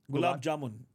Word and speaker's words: gulab jamun: Am I saying that right? – Gulab jamun. – Gulab jamun. – Yeah, jamun, yeah. – Gotcha. – Great. You got it.